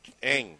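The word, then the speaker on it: Ng: Eng.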